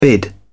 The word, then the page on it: bid: British English